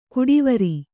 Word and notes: ಕುಡಿವರಿ: kuḍivari

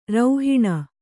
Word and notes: ರೌಹಿಣ: kannada

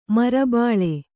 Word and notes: ಮರುಬೆಳೆ: maru beḷe

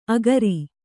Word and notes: ಆಖರಿ: kannada

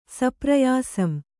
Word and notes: ಸಪ್ರಯಾಸಂ: kannada

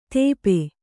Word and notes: ತೇಪೆ: tēpe

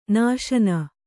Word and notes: ನಾಶನ: nāśana

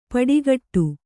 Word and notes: ಪಡಿಕಟ್ಟು: kannada

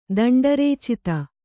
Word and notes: ದಂಡರೇಚಿತ: danḍa rēc ita

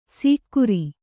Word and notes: ಸೀಕ್ಕುರಿ: sīkkuri

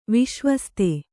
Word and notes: ವಿಶ್ವಸ್ತ: viśvasta

